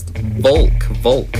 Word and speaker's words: Volk: And so we always say, we emphasize Volk, like I say, like pronounce it like an ignorant American like me, and say Texan, and say Volk.